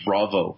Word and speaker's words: Vravo: The best example, we actually used it for our pitch for this program, is a company called Vravo. It's like Bravo, but spelled with a V.